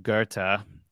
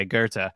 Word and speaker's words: Goethe: And Faust by a guy named Goethe. That's how they pronounce it over there. But it looks like it's spelled Goth in English. But anyway, Goethe.